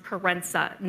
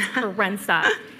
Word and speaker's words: Kerensa: And at one point, I interrupted, and I was like, sorry, so my name is pronounced Kerensa, not Mike. So if you have any other questions, it's Kerensa.